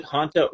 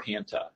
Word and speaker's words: hanta: You can pronounce it Hanta or Hanta. You hear a lot of people call it hantavirus. That's how I learned it in medical school, hantavirus.